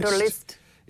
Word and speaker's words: Wunderlist: And that's what Wunderlist, or maybe it should be Wunderlist. – Wunderlist. – It's spelled W-U-N-D-E-R.